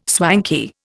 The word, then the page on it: swanky: Indian